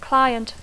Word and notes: client: English

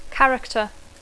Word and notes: character: English

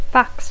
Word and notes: fax: English

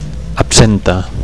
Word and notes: absenta: Catalan

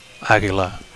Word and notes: aguila: Catalan

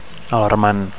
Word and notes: alarmant: Catalan

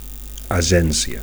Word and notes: agencia: Catalan

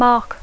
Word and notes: mark: English

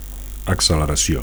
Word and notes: acceleracio: Catalan

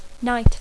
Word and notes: night: English

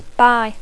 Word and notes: buy: English

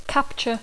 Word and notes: capture: English

